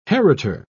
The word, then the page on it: heritor: British English